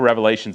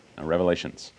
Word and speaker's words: Revelation: And just so you know, it's pronounced Revelation because there's only one Revelation. Revelations, is the final book of the New Testament that records the prophetic vision that was given by Christ to the Apostle John.